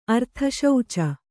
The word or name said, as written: ಅರ್ಥಶೌಚ